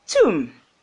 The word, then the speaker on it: tomb: You know that in tomb, the B is silent, so we pronounce it as tomb.